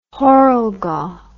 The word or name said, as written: heuriger